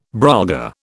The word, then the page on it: brolga: British English